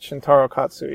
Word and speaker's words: Shintaro Katsu: With apologies to my pronunciation, I have it as Shintaro Katsu. – Yeah, that's it. Shintaro Katsu, yeah.